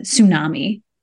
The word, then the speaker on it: tsunami: misleading so you think about words like uh, tsunami right so this is a word where we have that silent letter we don't know where it comes from but we know it's just pronounced with an s there